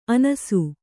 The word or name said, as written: ಅನಸು